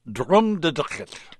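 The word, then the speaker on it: Drumnadrochit: And how do I pronounce Drumnadrachit. – Drumnadrachit.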